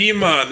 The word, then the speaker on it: Idi Amin: That's total speculation, you know, but Idi Amin. Does that sound right? – Idi Amin. There you go.